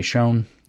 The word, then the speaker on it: Schön: In The Reflective Practitioner, Reimers tells us, a classic book on professional practice and education, Donald Schön, it's spelled like the word for beautiful in German, but it doesn't have an umlaut. I don't know what we're doing with this. Schön, S-C-H-O-N. We're going to say Schön.